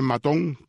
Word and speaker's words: Maton: se escribe Maton, pero se pronuncia Maton, J.T.